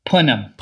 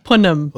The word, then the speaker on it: punim: Shayna. Repeat after me. Shayna. Punim. – Punim. – Punim. – Punim. – Yeah. And it's Yiddish for pretty face.